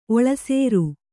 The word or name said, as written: ಒಳಸಾರ